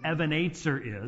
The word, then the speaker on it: Ebenezer: So that's what an Ebenezer is. And that's actually how it's pronounced. And so, you know, we could pronounce it correctly when we sing it. And that would also differentiate it from the guy named Scrooge,